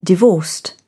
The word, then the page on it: divorced: British English